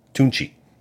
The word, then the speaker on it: Tunechi: As a team, the Astros gave Lil Wayne a jersey, and on the back they had his other nickname, Tunchi, but it's spelled T-U-N-E-C-H-I.